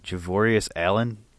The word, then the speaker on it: Javorius Allen: Javorius Allen for Baltimore.